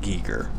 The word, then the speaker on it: Geiger: I think he's a Geiger. – He pronounces it Geiger. – Yeah. – But I believe if you were German, you would pronounce it Geiger. But he does not.